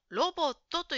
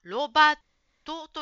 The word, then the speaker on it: ロボット: 1 番の答えのロボットという言葉ですが、ジムさんはロバットと言ってるように聞こえました。